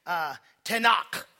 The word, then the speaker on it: Tanakh: It's called Tanakh. Everybody, and you roll your tongue. Tanakh. Everybody say that. God bless you. Say it again. Tanakh.